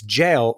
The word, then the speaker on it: JAL: Last week, so you were flying Japan Airlines and you were pronouncing the acronym JAL as JAL. – Uh-oh. – Our listener from Japan wrote in and said it's jail rhyming with pale.